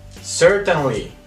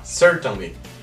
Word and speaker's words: certainly: Primera, Certainly. Certainly. Es un poquito difícil de pronunciar, pero si haces bien el sonido de la L no vas a tener problema. Certainly.